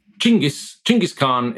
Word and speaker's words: Chinggis Khan: I think if you want to, you get Chinggis Khan is how most scholars say it properly, but I'm a pragmatist in this regard, rather than strictly politically sensitive and correct.